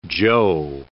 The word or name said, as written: jo